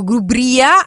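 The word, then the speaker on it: GruBrìa: Tutto questo organizzato da FIAB, c'è anche la merenda, e insomma andate sulla pagina del parco Grubria, che è appunto l'accento sulla I, andate a cercare così e trovate tutte le informazioni.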